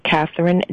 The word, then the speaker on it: Kathryn: And my first name is spelled K-A-T-H-R-Y-N, Catherine Davis, and you'll find me on Facebook.